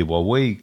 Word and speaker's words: Huawei: Но тут большой игрок, новый появился, который китайская Huawei, или Huawei, правильно там произносится,